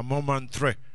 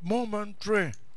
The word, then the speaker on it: Montmartre: El primer partido allá ganó a Montmartre. – Tremendo. – Pero no saben pronunciar, digo, Montmartre. ¿Por qué francés? No, Montmartre, dice. Y ahí viene Montmartre, dije yo. No, pues Montmartre.